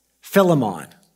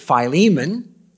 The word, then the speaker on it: Philemon: I've always pronounced it Philemon, and Haven Robinson said, if you really want to know how it's pronounced in English, it's Philemon. I'm not going to argue with Haddon Robinson, but if he doesn't mind, I'm going to call him Philemon.